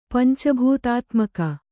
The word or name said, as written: ಪಂಚಭೂತಾತ್ಮಕ